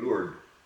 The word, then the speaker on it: Lourdes: The French call her Our Lady of Lourdes. They drop the S off.